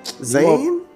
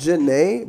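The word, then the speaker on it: Zhané: Zane? – That's what I thought too, but yeah, it's pronounced wild with these names, B. – Bro, that's old. – It's pronounced Janae, but it's spelled Zane.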